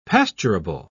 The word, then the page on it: pasturable: British English